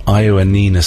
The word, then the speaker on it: Ioannina: They're from Greece, so I'm going to take my time with the pronunciation. The band are called Villagers of Ioannina City, and that single is called Venceremos. Yeah, I think I've got that right. Looks like it. – I'm happy with that.